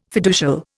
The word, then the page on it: fiducial: Indian